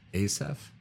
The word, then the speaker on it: Asaph: Well, yes, it's a prayer, actually, a song or a psalm of Asaph. Is that how you say his name? – Yep.